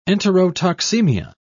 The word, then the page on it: enterotoxemia: British English